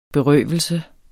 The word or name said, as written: berøvelse